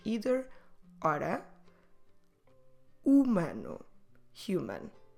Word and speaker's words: humano: Humano. H, U, M, A, N, O. Humano. Let's go through them again and make sure you don't pronounce the H at the beginning.